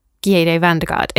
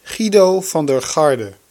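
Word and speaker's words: Giedo van der Garde: It was pointed out to me via Twitter that you don't say it like that. You say it like this. – Guido van der Garde.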